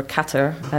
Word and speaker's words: Qatar: I actually didn't go to Qatar, or Qatar, as it's pronounced.